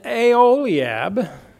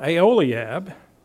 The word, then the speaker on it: Aholiab: And Aholiab, who worked under him, Aholiab, these names are not real easy to say, but I listened to all the pronunciations online, how you say them. Aholiab,